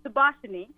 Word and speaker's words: Subashini: It is a Sri Lankan native name, Subashini, but it means sunshine.